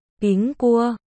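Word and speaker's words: Ping Cua (苹果): Trong tiếng Trung, quả táo được phát âm là Ping Cua, đồng âm với chữ bình trong từ đêm bình an.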